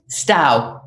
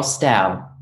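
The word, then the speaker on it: Stough: I can't pronounce his last name. Carl Stougard? – Stau. – Stau. – It's S-T-O-U-G-H, Carl Stau. – Stau.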